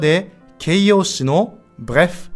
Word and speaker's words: bref: フランス語では一体どのように言うでしょうか正解は Bref Bref Bref 発音も短く一息で Bref ブレフとならないように気をつけましょうとにかく要するにという意味の副詞で、通常、まとめて言いたい文の最初に言います。